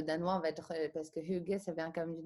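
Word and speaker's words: hygge: C'est ça ça reprend l'esprit Heige, si je le prononce bien. Ah, attends, reprononce-le parce qu'on ne l'a pas entendu malheureusement. – Hugue. – Hugue, ok.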